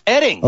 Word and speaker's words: Oetting: It's pronounced Edding. – But it's spelled O-E-T-T-I-N-G. – Yes, and O-E-T-T-I-N-G. It's pronounced Edding. – Okay, so it's like the O is silent, O-E-T-T-I-N-G is Edding. Got it.